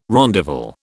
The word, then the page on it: rondavel: British English